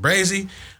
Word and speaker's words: Brazii: Brazii, I just like that the way it sounds, and then the two eyes when I saw it, how it looked, just Brazii.